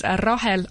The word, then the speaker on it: Rahel: I've got a page which is under my name, which is Rahel Otsa. – You better spell that. – R-A-H-E-L-O-T-S-A.